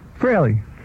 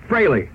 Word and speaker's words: Frehley: Did I say his name right? – Frehley. – Frehley. – Yeah, some people say Frehley.